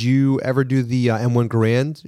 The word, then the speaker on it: Garand: Garand. – Oh, see, like I just learned something. – Garand rhymes with errand.